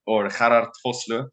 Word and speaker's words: Vosloo: My name is Gerhard Vosloo. As I'm speaking to South Africans, I can pronounce it in the proper Afrikaans way.